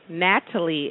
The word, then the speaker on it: Natalee: Well, not Natalie. That I don't know what my mom was thinking. Love you, Mommy. But it's Keisha, K-E-I. S-H-A. Natalie is N-A-T-A-L-E-E.